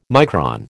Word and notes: micron: American English

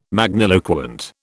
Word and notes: magniloquent: British English

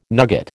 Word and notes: nugget: American English

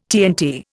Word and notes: TNT: Indian

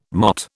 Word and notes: MOT: British English

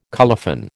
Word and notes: colophon: American English

